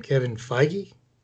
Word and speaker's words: Kevin Feige: I hope I don't mispronounce the name, Kevin Feige.